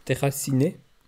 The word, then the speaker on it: Déraciné: Warum tust du es denn auf Französisch aussprechen? – Weil es so heisst. – Déraciné.